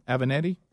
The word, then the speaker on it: Avenatti: Avenatti.